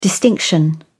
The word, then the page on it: distinction: British English